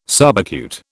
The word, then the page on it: subacute: British English